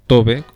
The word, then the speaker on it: Tove: En este programa hablaremos de la finesa finlandesa Tove Jansson, o Tove, como algunos la llaman, porque se escribe T-O-V-E, y su nombre completo es Tove Marika Jansson.